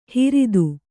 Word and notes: ಹಿರಿದು: kannada

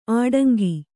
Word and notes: ಆಡಂಗಿ: āḍaŋgi

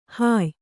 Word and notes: ಹುಯ್ಯು: huyyu

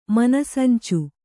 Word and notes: ಮನಸಂಚು: Kannada

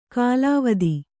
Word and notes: ಕಲಾವತಿ: kalāvati; Kannada

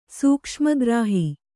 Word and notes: ಸೂಕ್ಷ್ಮಗ್ರಾಹಿ: sūkṣma grāhi

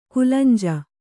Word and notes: ಕುಲಂಜ: kulanja

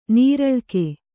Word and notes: ನೀರಳ್ಕೆ: nīraḷke

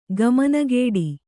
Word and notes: ಕಮಾನುಗಾಡಿ: kamānugāḍi

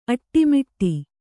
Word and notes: ಅಡಿಮುಡಿ: kannada

